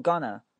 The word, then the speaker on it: gonna: 那继续往后看，出现一个词 gonna，G-O-N-N-A，它读 gonna。不要读成刚拿，它不是拿，后面它不是用手去拿的拿，它是呢，gonna gonna gonna gonna na na，它不是拿，是呢。 OK